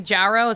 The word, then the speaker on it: Jarreau: Jarreau is how I say it because I'm from the Deep South.